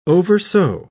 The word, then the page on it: oversew: British English